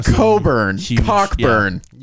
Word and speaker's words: Cockburn: Last week, I called Kofi Cockburn, and it's Coburn. Apparently, that CK is silent.